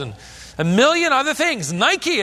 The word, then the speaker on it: Nike: Nike. We pronounce it Nike. It means victory.